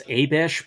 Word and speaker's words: Abish: Now, according to the pronunciation guide in the Book of Mormon, it's Abish.